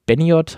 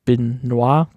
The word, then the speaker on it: Benoit: über Chris Be Beniot heißt der, ähm, oder Benoit, glaube ich, wird das ja ausgesprochen, Chris.